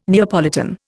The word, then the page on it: Neapolitan: Indian